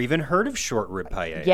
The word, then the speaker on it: paella: Am I saying it right? – Yeah, the paella.